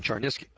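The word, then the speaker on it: Cherniske: His name is Stephen, it's spelled S-T-E-P-H-E-N. Char, C-H-E-R, Niske, N-I-S-K-E, Stephen Charnisky.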